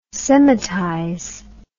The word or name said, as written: semitize